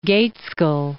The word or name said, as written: Gaitskell